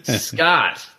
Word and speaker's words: Scott: Rhymes with hot. – Scott, thank you.